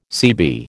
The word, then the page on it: CB: American English